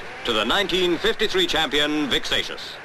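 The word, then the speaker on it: Seixas: And one of those 24 is this man, Vic Satius. – Rhymes with gracious. It's like the word spacious without a P.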